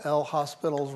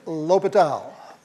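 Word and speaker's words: L'Hôpital: We use the good old L. Hospital's rule. Okay, it's L'Hopital.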